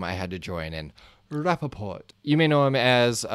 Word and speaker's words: Rapaport: We have Michael Rapaport. – Rapaport? – Sorry, what did I say? – Oh, no, you said it right.